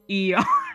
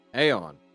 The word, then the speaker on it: Aeon: I'm gonna try to wave over, I've been mispronouncing it on purpose this time. Eon. I'm gonna say, Eon, get over here. – Aeon.